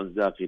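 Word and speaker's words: Inzaghi: انزاغ، انا اقوله انزاغ، انا اقوله بالنطق النطق الايطالي، حتى الايطاليين ما يقولون انزاغي ما عندهم قاء.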